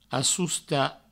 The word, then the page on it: assostar: assousta